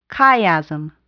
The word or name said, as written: chiasm